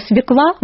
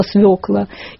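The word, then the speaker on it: свёкла: Такой классический пример борцов за букву Ё – это люди стали говорить слово «свекла» вместо слова «свекла», «афера» вместо «афера».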